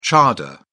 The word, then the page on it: chador: British English